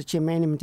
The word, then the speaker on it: germanium: So the germanium detector, am I saying that correctly? – Yes, a germanium detector.